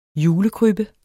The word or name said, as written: julekrybbe